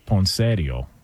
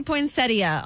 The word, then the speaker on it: poinsettia: Well, the in-laws would call it poinsettio. – Oh, gosh. – Okay. What up? You know what I'm saying? – Oh, God. – Can we get the proper pronunciation on this? – It does say poinsettia online.